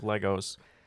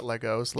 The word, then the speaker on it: Lego: You have a beautiful Home Alone house behind you made out of Legos. Very, very important question. – Yes, it's pronounced Lego, not Legos.